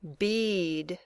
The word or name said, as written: bead